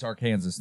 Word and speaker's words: Arkansas: It's Arkansas, thank you.